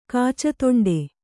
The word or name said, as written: ಕಾಚತೊಂಡೆ